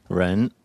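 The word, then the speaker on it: Ren (人): Ren.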